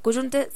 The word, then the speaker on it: Cullunche: Cuyunche se escribe C-U-L-L-U-N-C-H-E.